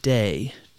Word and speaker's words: Te: and they're going to use the word day um, which is again spelled with a T again T E H, but it's um, it's it's going to be the the other principle.